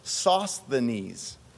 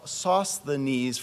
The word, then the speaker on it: Sosthenes: He also then includes this person, Sosthenes. And I know we've been in German a long time because Sosthenes for me is really hard to pronounce. That S and TH, that's really difficult for German speakers.